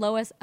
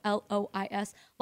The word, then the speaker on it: Lois: And then my first name, Lois, Lois. A lot of people say there's a U in it. I don't really hear it.